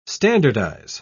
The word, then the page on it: standardise: British English